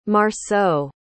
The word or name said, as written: marceau